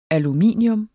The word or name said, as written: aluminium